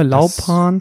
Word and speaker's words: Laupan: Habe ich den Namen richtig ausgesprochen? Das ist immer meine erste Frage. – Laupan. Das, das war korrekt, ja.